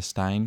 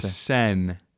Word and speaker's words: Seine: We went on the boat tour of the Seine River. – And oh my goodness, you just butchered that so bad. It's the Seine.